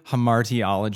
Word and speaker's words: hamartiology: Hamartiology.